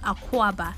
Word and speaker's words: Akwaba: So when you come to Ghana and they say Akwaba, it's pronounced Akwaba.